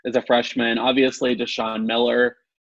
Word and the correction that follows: Drayshon: Or Drayshon Miller, sorry, I pronounced his name wrong.